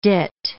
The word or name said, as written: dit